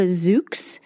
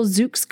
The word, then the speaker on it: Zoox: It is called the Zoox. And if you want to look that up online, it's spelled Z-O-O-X.